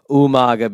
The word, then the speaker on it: Umaga: And of course, you have to say it like Armando would say, Umaga,